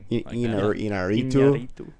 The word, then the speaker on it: Iñárritu: Iñárritu,